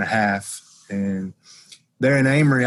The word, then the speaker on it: Amory: Well, you know, I'm from Amory, Mississippi, A-M-O-R-Y, but it's pronounced Amory.